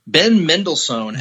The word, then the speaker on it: Ben Mendelsohn: Standout number two I wanted to talk about in the cast was, trying to find this guy's name, Ben Mendelsohn as Talos. Did I say his name right, Nick? – Mm-hmm.